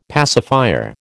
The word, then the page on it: pacifier: American English